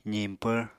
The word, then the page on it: ziñpvr: apatani